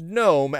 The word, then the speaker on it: GNOME: So the GNOME project, and again, it is pronounced GNOME as in garden gnome, not GNOME as in not a garden GNOME, is closing all of their mailing lists.